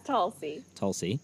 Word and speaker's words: Tulsi: The one thing that you liked was Tulsi. Am I saying that right? – Yeah, Tulsi.